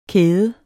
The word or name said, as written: kæde